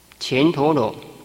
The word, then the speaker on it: 乾陀罗: 这个乾字哈、哦，也念作干，干字哈、哦、有两个读音，第一个读音是乾，如同乾坤，乾隆皇帝；第二个读音是干，如同干净、干燥。因为这是国名，所以读作乾，乾陀罗。